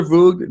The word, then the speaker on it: Voogd: So I recommend that you guys take a minute, check out Peter Voog. Voog is spelled V-O-O-G-D. That's V as in Victor, O-O, G as in girl, D as in dollars, Peter Voog.